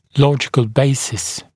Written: logical basis

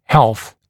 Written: health

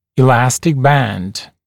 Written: elastic band

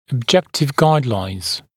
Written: objective guidelines

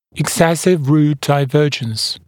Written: excessive root divergence

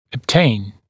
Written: obtain

